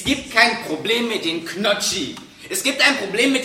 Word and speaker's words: Gnocchi: Folgt ein N auf ein G, dann muss man es wie K aussprechen. Das ist die Regel. Darauf muss ich leider bestehen. – Es heißt aber Gnocchi!